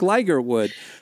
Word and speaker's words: Ligertwood: You're probably like, "Who's Brooke Lidgertwood?" Because for 10 years, you have been telling us about Brooke Ligertwood. We found out. I have been saying her name wrong.